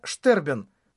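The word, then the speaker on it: sterben: Это немецкий медицинский термин. Произносится «штербен».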